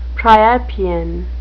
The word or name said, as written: priapean